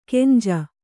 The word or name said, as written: ಗಂಜು